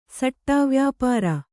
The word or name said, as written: ಸಾಟೆವ್ಯಾಪಾರ